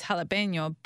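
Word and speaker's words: jalapeño: I mean, the correct pronunciation, if you're speaking Spanish, it's jalapeno, but...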